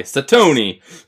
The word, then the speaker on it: Satony: S-A-T-O-N-Y. Satoni.